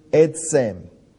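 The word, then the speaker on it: etzem (עצם): The Hebrew word for bone that's translated here for us is the word etzem. And it's spelled ein zadeh mem, etzem.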